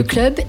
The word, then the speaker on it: Kl'hub: Parce que ça s'écrit K-L-H-U-B. – Ouais, avec une petite apostrophe d'ailleurs. C'est le club alors définitivement, le club? – C'est le club et c'est l'idée que c'est un hub.